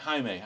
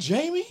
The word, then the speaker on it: Jaime: When this movie started and they were like, Jaime, I was like, his name's not Jamie? – Yeah.